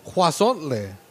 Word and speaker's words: huauzontle: Well, it's called huazontle. Huazontle, apparently. – How do you spell that? – H-U-A-U-Z-O-N-T-L-E.